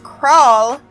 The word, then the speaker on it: kraal: So it is pronounced kraal, and it is a traditional African village of huts typically closed by a fence.